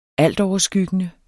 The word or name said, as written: altoverskyggende